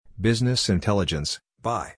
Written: Business Intelligence (BI)

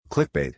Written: Clickbait

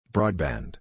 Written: Broadband

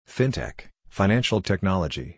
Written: Fintech (Financial Technology)

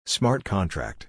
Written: Smart Contract

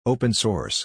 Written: Open Source

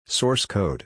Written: Source Code

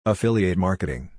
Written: Affiliate Marketing

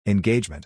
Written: Engagement

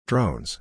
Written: Drones

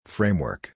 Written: Framework

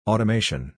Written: Automation

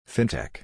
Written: Fintech

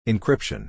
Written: Encryption

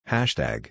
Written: Hashtag

